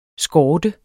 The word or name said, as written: skorte